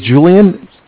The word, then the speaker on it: Julian: Okay, my name is Julian. It's J-U-L. Yeah, J-U-L-I-A-N.